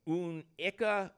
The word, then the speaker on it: Anneke: So first I'm going to spell it A-N-N-E-K-E. And then I'm going to tell you how she says it's pronounced. Unica Unica in Lake Leelanau, Michigan.